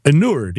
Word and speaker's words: inured: And because I think you become inured. You stop in, did I say that right? – Inured? Did you say inured? – Should I say inured? How do you say it? – Oh, inured. Yeah.